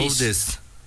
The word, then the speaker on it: Otis: Audes. – El señor Otis. – Sí, Audes. Y se escribe Otis.